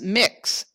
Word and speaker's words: Mx: and I believe it's pronounced mix.